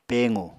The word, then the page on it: pequ: Apatani